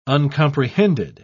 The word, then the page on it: uncomprehended: British English